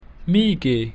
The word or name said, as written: mihi